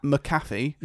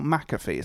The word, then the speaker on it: McAfee: I mean, his name is spelled M-C-A-F-E-E, so it's McAfee. So it's McAfee. I don't understand why people call him Pat McAfee. There's no A in between the M and the C.